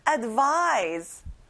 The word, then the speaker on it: advise: V 발음을 정확하게 해보는 거예요. 그리고 vise에 강세를 꼭 넣어보세요. Advise.